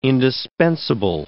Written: indispensable

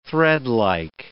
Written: threadlike

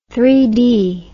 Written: three-cornered

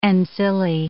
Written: ancillae

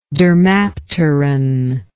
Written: dermapteran

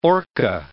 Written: orca

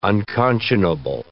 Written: unconscionable

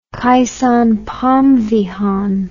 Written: Kaysone Phomvihane

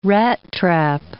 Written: rattrap

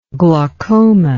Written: glaucomatous

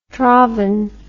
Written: traven

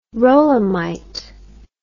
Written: rolamite

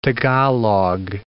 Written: Tagalog

